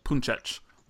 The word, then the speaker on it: Puncic: God help me as I say his name, Roberto Puncic. I think is probably how you say it. Puncic, something like that. – I think the first one was right, man. That's pretty damn close.